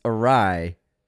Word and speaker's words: Ari: Funny thing is, still says it incorrectly. – Thank you. – Though he claims is wrong. Guys, it's Ari.